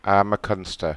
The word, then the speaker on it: McCunster: McCunster.